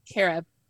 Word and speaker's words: Cari: Carob, just like the health food. – Oh, okay. Carob. Carib. – Carib.